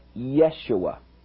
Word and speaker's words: Yeshua: Now, in Hebrew, there are no J's. There are only Y's. So this would be pronounced more like Yeshua.